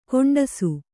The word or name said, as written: ಖಂಡಿಸು